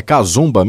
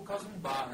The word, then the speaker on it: casumba: Esse casvimba, é isso que como que fala essa pronúncia? É casumba mesmo? Casumba, é, assim, é, pode se chamar casumba ou casumbá, né?